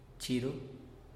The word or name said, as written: chiru